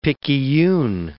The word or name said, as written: picayune